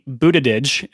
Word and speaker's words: Buttigieg: So who we have for sure announced, we have Mayor, this is Mayor Pete Buttigieg, is how you pronounce that, of South Bend, Indiana.